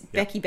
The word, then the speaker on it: Bekky: my name is Bekky Bekky Bekky, but it's spelled K Y.